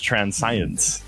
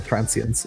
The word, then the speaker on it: transience: Because last time it was transcience. – this time you said transience.